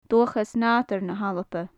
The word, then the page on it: dualchas nadair na h-alba: gaelic